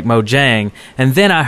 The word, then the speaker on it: Mojang: He was Swedish, and he told us that it was not Mojang, it was Mojang. That's how you pronounce it.